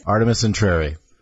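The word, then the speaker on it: Artemis Entreri: Today we are going to be going over Artemis Intrary. Is it, how am I saying? Mm-hmm. Am I saying that is correct? – Okay, I'm like, am I butchering this? – Artemis Intrary.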